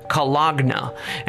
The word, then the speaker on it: cologne: Interestingly, though, we're confronted with some cologne, pronounced Cologna, and this wouldn't be the last time.